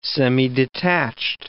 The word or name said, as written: semidetached